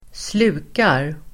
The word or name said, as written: slukar